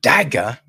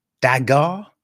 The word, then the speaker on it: Dagr: Okay, people, so thanks to Kimmy and Shirley at Studio Dome, the Horror Collective, just been able to check out Dagger, I think it is pronounced D-A-G-R, right?